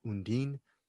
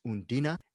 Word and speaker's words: Undine: I thought it was initially called Undine, but the way they pronounce her name is Undina, and Undina is based on a, I think, a myth, a, a myth of a water nymph, Bruce Purkey, sort of.